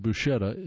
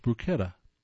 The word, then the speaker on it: bruschetta: The letter SH should be pronounced SK, as in scheme, and not SH, as in she, so it's bruschetta. That's interesting. John Kellett, Los Orsos, California. From the editors, our go-to reverence, Merriam-Webster's Collegiate Dictionary, says that both pronunciations are correct, but SH is preferred.